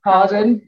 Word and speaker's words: Hawarden: And it's based in the village of, and there's a specific way to pronounce this. Is it Harden? – Harden. Well done. Gold star. – Yeah. Spelt Hawarden, in North Wales.